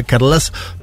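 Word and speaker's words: Carles: Consiguió los apoyos del independentismo catalán, eh, eh, sobre todo la formación Junts de Carlax Puigdemont, lo digo porque un oyente me dijo cómo se pronunciaba, Carlas Puigdemont.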